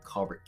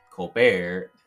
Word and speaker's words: Colbert: who were telling him that in Colbert County we pronounce it Colbert, to where he pronounces Stephen Colbert, as I said, as Colbert. So there is a little bit of differentiation in how you pronounce the name.